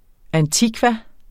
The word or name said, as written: antikva